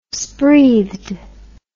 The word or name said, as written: spreathed